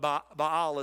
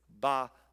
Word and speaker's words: Baal: But Baal is the proper pronunciation. It's actually like two syllables, believe it or not. It's Baal.